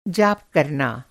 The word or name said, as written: जाप